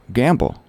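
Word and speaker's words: gambol: G-A-M-B-O-L. I've heard it. Run or jump about playfully. How do you pronounce it, though? Gamble. Yeah, gamble. Just like rolling the dice in Las Vegas.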